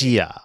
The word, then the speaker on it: Asia: I don't fucking whatever how you pronounce Asia.